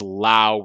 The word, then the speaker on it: Lau: It is Lau rhymes with now, not low rhymes with low.